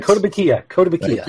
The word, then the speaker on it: Kotobukiya: Kotobukiya. I hope I'm saying that right. – Kotobukiya.